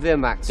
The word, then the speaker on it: Vermax: He says Vermax, like V-E-E-R. – It's good you're here, young prince. You're needed to patrol the skies on Vermax. – Did you hear what I said? – Vermax. It's V-E-R-M-A-X, so I would say Vermax.